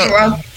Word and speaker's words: Angela: All right, Angela, am I pronouncing your name right? Is it Angela? – Angela. – Okay. I thought it was like Angelina.